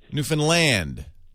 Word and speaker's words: Newfoundland: Newfoundland. – Newfoundland. It's kind of like Newfoundland. You put the emphasis on the land, and you put less emphasis on the...